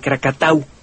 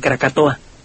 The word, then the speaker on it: Krakatau: Esa isla produjo una explosión similar a la de Krakatau, que los holandeses mal pronunciaban Krakatoa.